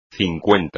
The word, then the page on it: cincuenta: espagnol